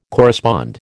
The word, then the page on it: correspond: American English